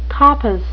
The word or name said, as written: coppice